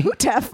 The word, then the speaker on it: Houteff: And they're founded in 1929 by Victor Hutef. – Wow. – Now, I'm definitely saying that incorrectly. Matt, it's spelled H-O-U-T-E-F-F. – Sounds right to me. – Hootef.